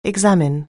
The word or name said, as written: examine